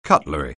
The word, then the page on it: cutlery: anglais